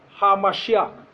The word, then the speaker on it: HaMashiach: When you hear the word, the Christ in the Hebrew is pronounced HaMashiach.